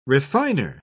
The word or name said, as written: refiner